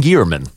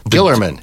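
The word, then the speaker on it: Guillermin: You told me on the phone there was first of all the director John Gierman. – Gillerman.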